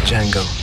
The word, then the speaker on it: Django: Django. D-J-A-N-G-O. The D is silent.